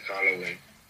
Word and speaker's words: Halloween: Feliz Howell. ¿Cómo se dice? – Feliz Halloween.